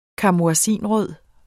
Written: karmoisinrød

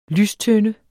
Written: lystønde

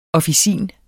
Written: officin

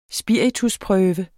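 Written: SPIRITUSPRØVE

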